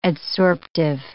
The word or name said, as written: adsorptive